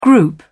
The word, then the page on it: group: British English